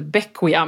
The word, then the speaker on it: Bequia: Det stavas B-E-Q-U-I-A. Ja. Så när jag åkte hit så trodde jag att det hette Bequia, men det uttalas Bequia.